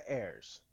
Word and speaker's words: Heirs: It's The Heirs.